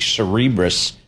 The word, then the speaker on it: Cerebras: Cerebras, I think, is kind of how we figured out how to pronounce it.